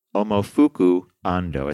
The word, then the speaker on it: Momofuku Ando: Momofuku Ando. It's pronounced the way it's spelled, right? – Momofuku Ando.